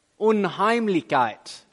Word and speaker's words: Unheimlichkeit: it's the word unheimlichkeit. Is that right? I don't know, right? It's a German word, unheimlichkeit. How do you say it? Unheimlichkeit. Woo, okay. And by that